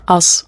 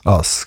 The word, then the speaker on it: Us: Und momentan veröffentlichst du auf dem Zürcher Label Us, oder wie spricht man das aus? – Us. – Us, genau.